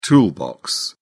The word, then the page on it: toolbox: British English